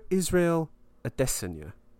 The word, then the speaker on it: Israel Adesanya: and it's about Israel Adesanya.